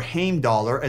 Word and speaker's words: Heimdallr: Heimdall, or Heimdallr as it's actually pronounced,